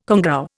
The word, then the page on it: congou: Indian